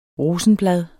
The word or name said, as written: rosenblad